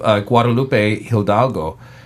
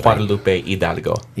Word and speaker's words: Guadalupe Hidalgo: I will say, though, that the Treaty of Guadalupe Hidalgo, am I saying that right? – Yeah.